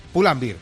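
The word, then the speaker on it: Pull&Bear: Pull-and-beer.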